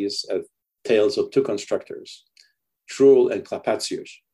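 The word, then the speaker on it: Trurl: The first one's Truel. It's for our listeners, it's T-R-U-R-L, Truel.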